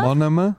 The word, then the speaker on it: monomer: Monomer? – Monoma. – Monoma. – It's pronounced. – Monoma.